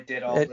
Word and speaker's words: Dettol: That Dettol, is that how we pronounce it? – Yeah, Dettol, that's fine.